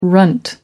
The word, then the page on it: runt: American English